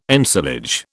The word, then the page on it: ensilage: British English